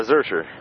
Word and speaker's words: Zurcher: Zurcher. – How do you spell it? – With a Z-U-R-C-H-E-R.